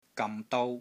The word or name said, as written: GAM6 DOU1